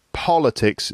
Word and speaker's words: politics: Politics is the first syllable, has the stress.